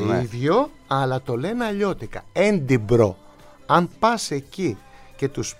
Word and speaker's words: Edinburgh: Φτάνεις εκεί και σου λένε Edinburgh. Καμία σχέση με τη γραφή.